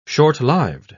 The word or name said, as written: short-lived